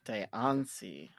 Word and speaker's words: Diancie: Diancie.